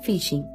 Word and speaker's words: phishing: La técnica utilizada en el supuesto bono mujer se llama phishing. La palabra se pronuncia igual que phishing, en inglés, es decir, pescar.